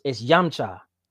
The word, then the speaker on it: Yamcha: So it's Yamcha. – Okay, so I'm so sorry. Cool, I said it the wrong way. Yumchi.